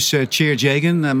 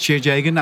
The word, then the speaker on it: Tjeerd Jegen: So my name is Tjeer Jagan. My mother assumed I would never leave native Holland, so a bit difficult to pronounce, but it's Tjeer Jagan.